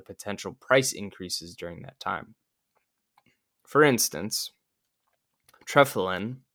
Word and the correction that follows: Trefethen: Wow, I can't even pronounce this right. Trefethin. Yeah, Trefethin. Sorry, it's Trefethen.